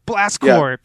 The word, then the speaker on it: Blast Corps: How do you say that? – Is it Corps? – It's just like Contra Hard Corps, but as a kid, again, it was like Hard Corps, Blast Corps.